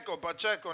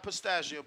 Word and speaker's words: Pacheco: Pacheco, not Pistachio, Pacheco.